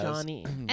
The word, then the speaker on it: Johne: J-O-H-N-E. – Yes. – Johnny. – And then today in our thing, you said, in parentheses, Johnny, J-O-H-N-N-Y. – Well, that's how you say it. Oh, right. Because I was just saying that's how you pronounce it.